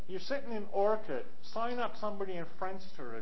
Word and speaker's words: Orkut: Or think about Orkut, or no, actually no, it's pronounced Orkut.